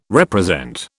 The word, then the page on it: represent: British English